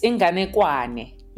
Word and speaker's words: Ingane Kwane: And then one of them was, and please tell me if I'm pronouncing this correctly, because I want to get it right. Ingane Kwane. – Yes, Ingane Kwane.